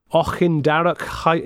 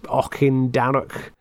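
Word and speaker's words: Auchindarroch: Ochindarrock in the Highlands. So it's A-U-C-H-I-N-D-A-R-R-O-C-H. Ochindarrock.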